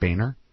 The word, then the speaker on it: Boehner: House Republican leader the Boner. – I believe it's pronounced Boehner.